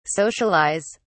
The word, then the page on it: socialise: British English